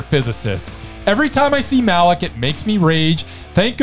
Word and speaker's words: Malek: Rami Malek. It rhymes with ham hock. Hammock. Hammock. – Pammy mammock. – Hammock. – The hammock. – Lammy stammock. – Banana hammock. – Yes.